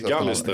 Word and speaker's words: Gallister: Men det var den här den eminente Andreas, vet du hur man uttalar det? Gallister. – Gallister, säger man så.